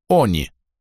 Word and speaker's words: они: У них имелось общее название «они» с ударением на первый слог.